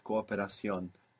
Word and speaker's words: cooperación: You pronounce both O's. That was correct. Cooperación. Just sounds a tiny bit longer, no?